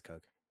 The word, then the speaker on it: Koch: Oh, it's pronounced Coke. I Googled it. – Coke? – Oh, it's Coke? – It's German, right? They have a different pronunciation for it. – Yeah, it is Coke.